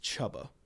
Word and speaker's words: Csaba: By the way, the name is pronounced Chubba. – Who is Chubba? – Who's Chubba? – C-S-A-B-A.